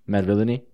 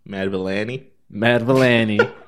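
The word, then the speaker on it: Madvillainy: It's Mad Villainy. Yeah. – Okay. I've been saying that wrong this whole time. – What were you saying? – Mad Villainy.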